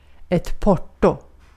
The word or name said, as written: porto